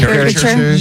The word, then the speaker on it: caricature: I would like the I can't ever pronounce this word, the character artist. – Oh, caricature.